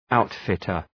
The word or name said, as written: outfitter